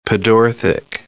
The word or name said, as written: pedorthic